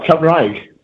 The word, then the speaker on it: Cymraeg: Same rag, am I pronouncing this right? Can you tell us about the Māori? Or look, maybe you should say it, and then I'll get a handle on it. – Oh, Cymraeg, yeah, which is the Welsh language.